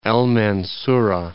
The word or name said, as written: El Mansura